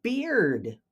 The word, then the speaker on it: beard: When you add the D, it makes the B-E-A-R change pronunciation from bear with a long A to beard with a long E.